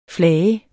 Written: FLAGEDE

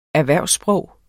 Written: erhvervssprog

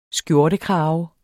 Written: skjortekrave

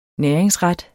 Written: næringsret

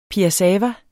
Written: piassava

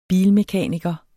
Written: bilmekaniker

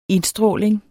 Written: indstråling

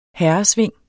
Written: herresving

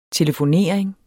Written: telefonering

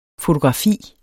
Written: fotografi

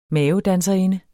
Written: mavedanserinde

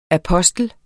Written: apostel

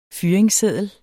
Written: FYRINGSSEDDEL

